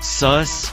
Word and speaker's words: Suss: All right, so is it pronounced Sus or Seuss? Sus. I was hoping for Seuss.